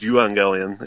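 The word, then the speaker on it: euangelion (εὐαγγέλιον): Uh, evangelion, or in Greek would be pronounced euangelion, is just the word for gospel, good news.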